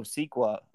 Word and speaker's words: Sikoa: him and Solo Sikoa, how do you pronounce Sikoa.